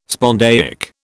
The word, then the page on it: spondaic: British English